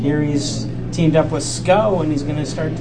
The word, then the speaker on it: Schou: And SCO is spelled S-C-H-O-U, but fortunately their website explains how it's pronounced, and it's pronounced SCO.